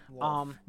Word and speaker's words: Lolth: Or Loth. Loth. I'm sorry. Loth. That was her name. Loth was her name. – Spell that. – L-O-L-T-H. Loth.